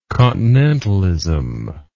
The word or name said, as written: continentalist